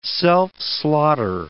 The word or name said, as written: self-slaughter